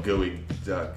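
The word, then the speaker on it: geoduck: So it's not spelled how you think either. I spelled it wrong. – It's spelled Gooey Duck. – It's G-E-O-K. – Gooey like Duck. – G-O-K. I spelled the G-O-E-E-Y.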